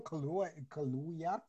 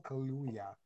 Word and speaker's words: Kaluuya: I told myself no more like trauma-filled Black movies, but this one, still, I might have Daniel Kaluuya. Sorry. – Mm-hmm. – Wow, I butchered that. Daniel Kaluuya.